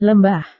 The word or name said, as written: lembah